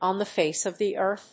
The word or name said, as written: on the face of the Earth